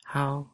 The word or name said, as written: hau